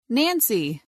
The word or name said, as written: Nancy